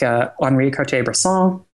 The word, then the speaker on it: Henri Cartier-Bresson: You'll definitely come across names like Henri Cartier-Bresson.